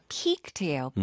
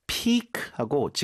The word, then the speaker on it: piqued: A few years ago, I saw a coworker solve a 3x3x3 cube at the office, and it piqued my interest. – Piqued. – Piqued가 아니라 piqued예요.